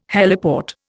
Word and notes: heliport: Indian

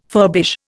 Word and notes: furbish: Indian

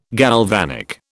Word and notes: galvanic: British English

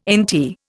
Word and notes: NT: Indian

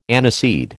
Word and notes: aniseed: American English

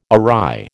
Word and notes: awry: American English

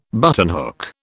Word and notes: buttonhook: British English

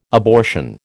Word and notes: abortion: American English

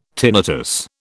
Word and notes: tinnitus: British English

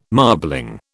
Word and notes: marbling: British English

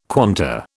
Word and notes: quanta: British English

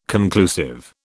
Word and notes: conclusive: British English

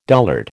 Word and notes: dullard: American English

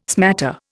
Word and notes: smatter: Indian

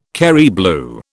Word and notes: Kerry blue: British English

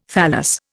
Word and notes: thallus: Indian

